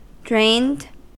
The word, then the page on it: drained: American English